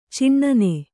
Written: ಚಿನ್ನಿ

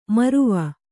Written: ಮೌರ್ವ

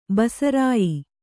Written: ಬಸರಾಯಿ

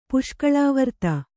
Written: ಪುಷ್ಕಳಾವರ್ತ

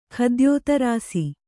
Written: ಖದ್ಯೋತರಾಸಿ

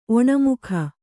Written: ಒಣಮುಖ

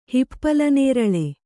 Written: ಹಿಪ್ಪಲನೇರಳೆ

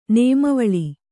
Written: ನೇಮವೇಳು

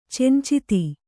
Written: ಚಂಚಿತಿ

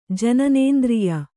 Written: ಜನನೇಂದ್ರಿಯ

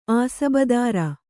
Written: ಆಸಬದಾರ

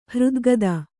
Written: ಹೃದ್ಗತ

